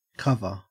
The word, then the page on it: cover: Australian English